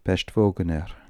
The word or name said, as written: Peshtvogner